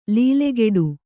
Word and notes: ಲೀಲೆಗೆಡು: līlegeḍu

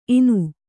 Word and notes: ಇನ: Kannada

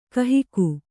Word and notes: ಕಾಹಿಗ: kāhiga

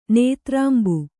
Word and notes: ನೇತ್ರಾಂಬು: nētrāmbu

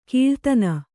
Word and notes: ಕೀಳ್ತನ: kīḷtana

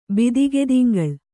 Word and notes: ಬಿದಿಗೆದಿಂಗಳ್: Kannada

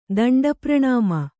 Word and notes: ದಂಡಪ್ರಣಾಮ: kannada

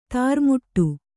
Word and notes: ದ್ರಾಮಿಡಿ: drāmiḍi

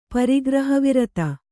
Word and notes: ಪರಿಗ್ರಹವಿರತ: pari graha virata

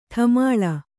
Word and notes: ಡಮಾಲು: ḍmālu

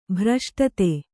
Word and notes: ಭ್ರಷ್ಟತೆ: kannada; bhraśṭate

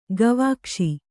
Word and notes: ಗವಾಕ್ಷಿ: gavākṣi

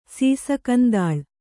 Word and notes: ಸೀಸಕಂದಾಳ್: Kannada

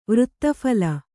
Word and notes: ವೃತ್ತಫಲ: vřtta phala

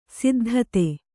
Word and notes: ಸಿದ್ಧತೆ: siddhate

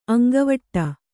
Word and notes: ಅಂಗವಡಿ: Kannada

